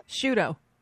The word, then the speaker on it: Sciutto: Is it Sciutto? S-C-I-U-T-T-O? – Yeah. Jim Sciutto. – CNN? – Yep. – How do you say it? – Sciutto. – It is Sciutto.